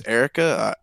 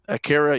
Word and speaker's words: Akira: And I don't know how to say it, but I guess it's Erica. I would say Erica, but I'm pretty sure it's pronounced some. – Akira? – That's number one. Akira, yeah, that's number one on this list.